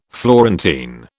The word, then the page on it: Florentine: British English